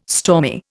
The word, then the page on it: stormy: Indian